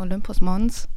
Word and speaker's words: Olympus Mons: Alles klar, dann hören wir uns jetzt das Lied Olympus Mons. Ist das richtig ausgesprochen? – Ich würde sagen Olympus Mons.